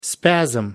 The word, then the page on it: spasm: American English